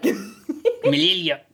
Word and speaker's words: Mjolnir: I loved her, and like I liked how you know, like she did all the research, and it's like, oh, Mjolnir can, I pronounced that so wrong, can cure me, and like Mjolnir.